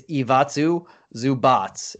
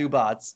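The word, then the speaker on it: Ivica Zubac: I'm not really into the – before I say his name, I do got to say, we both actually butchered it. I looked it up after we said it. It's Ivatsu Zubats. So we were both butchering it. I tried to call you. – Oh, like a Pokemon, Zubat. – Yeah, Zubat. Like a Zubats.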